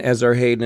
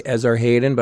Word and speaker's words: Esarhaddon: You know, we must be on the same wavelength, because as I was saying, and whenever I write it, read it, I say Esarhaddon, but it's probably Esarhaddon, because it's got two D's and an A. So it's probably Esarhaddon. British people say Esarhaddon.